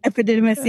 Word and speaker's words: epididymis: Epididymis, yes.